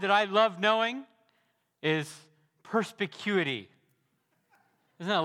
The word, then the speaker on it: perspicuity: I really do love the doctrine of perspicuity, but I also just plain love that word, perspicuity. I like how it sounds. I like that I know how to pronounce it, and that's how you pronounce it.